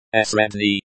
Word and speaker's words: средний: Pronounced, средний, stress on the first syllable.